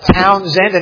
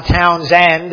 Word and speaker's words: Townsend: I said it right, Frederica, right? Not Frederica, and it's Townsend and not Townsend.